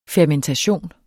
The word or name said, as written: fermentation